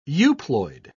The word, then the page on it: euploid: British English